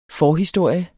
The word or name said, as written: FORHISTORIERNES